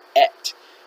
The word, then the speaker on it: et: In Hebrew, there is a direct object pointing word that is spelled Aleph Tav, Aleph Tav, and is pronounced et.